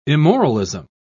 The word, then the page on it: immoralism: British English